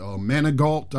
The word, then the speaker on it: Manigault: I can't pronounce her other name, but Manigault, I believe it is.